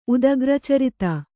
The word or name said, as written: ಉದಗ್ರಚರಿತ